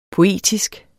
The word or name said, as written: poetisk